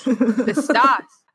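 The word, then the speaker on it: pistos (πιστός): But I did find that the Greek word of faithful is pistos, if that's how you pronounce it. Can you pronounce it? She just held it up to the screen. – Strong's G, 4103, pistos.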